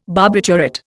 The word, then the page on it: barbiturate: Indian